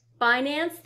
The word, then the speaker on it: Binance: Binance.